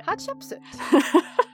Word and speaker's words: Hatshepsut: Hatshepsut.